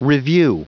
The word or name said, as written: revue